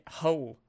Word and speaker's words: Hull: Well, I went up to a little town called Hull over the weekend to visit some friends. And Hull? – Then yeah, Hull, H-U-L-L. – H-O- oh, Hull.